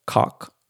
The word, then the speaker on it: Koch: Um, I told you guys in last episode that H and K, Heckler and Koch, actually it's pronounced cock, right? Like a German cock.